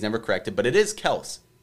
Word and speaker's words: Kelce: And he said his last name is actually pronounced Kels, not Kelce, which everyone calls him Travis Kelce.